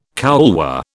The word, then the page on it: couloir: British English